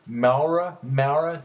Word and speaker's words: Maurer: It's David Mora. I'm probably pronouncing it wrong. M-A-U-R-E-R. Maura. David Maura.